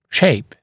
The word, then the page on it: shape: ʃeɪp; шэйп